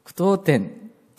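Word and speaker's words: 句読点: はい、この上は当点、下は句点、合わせて句当点と言いますね。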